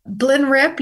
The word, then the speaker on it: Blenrep: Blenrep, you have pronounced it correctly, is one of the new immune treatments.